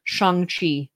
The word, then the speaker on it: Shang-Chi: I was saying it wrong. Shang-Chi. Shang-Chi. Like Sean Shang-Chi.